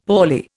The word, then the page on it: poorly: Indian